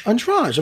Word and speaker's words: Andrade: And Jessica, I pronounce that last name for me. – Andrade. – Of course, I was going to say Andrade. – You were going to be right.